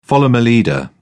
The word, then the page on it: follow my leader: British English